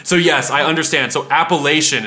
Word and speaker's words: Appalachian: If that's where the people who live in the Appalachian Mountains, if they pronounce it Appalachian, yes, then that is then that's the correct pronunciation.